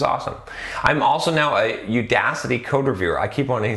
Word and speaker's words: Udacity: About a year and a half in, I decided to enroll in Udacity. See, I said it right. I didn't say Udacity. I said Udacity Nano degree for Android development, despite their prerequisite being 2 years of Java.